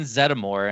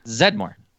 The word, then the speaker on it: Zeddemore: What is it? – Zeddemore. – No, it's not. There's an E in there. – Winston Zeddemore. – Whatever. He was in the movie for like 5 minutes. – The E is silent.